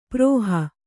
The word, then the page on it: ಪ್ರಹಿ: prahi